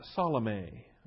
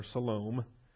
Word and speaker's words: Salome: Her name is Salome, or Salome. I've heard it pronounced different ways. Salome.